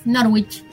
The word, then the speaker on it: Norwich: Sí, aparte así lo pronuncian los ingleses, Norwich. Mucho CH.